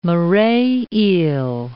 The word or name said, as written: moray eel